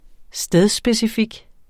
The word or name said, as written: stedsspecifik